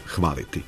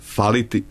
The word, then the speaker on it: faliti: Jedini oblik u ovom slučaju jeste faliti sa značenjem nedostajati.